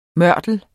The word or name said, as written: mørtel